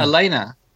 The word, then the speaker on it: Elena: Is this an N-A or N-Y-A? – Oh, it's really just Elena? – Yeah. – Elena. – I thought she was Hispanic. – She is. – Oh. But the E sound, that the E in Spanish is pronounced as a long A.